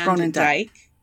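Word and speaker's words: Groenendyke: I mean, pronounced Gronendijk. Gronen.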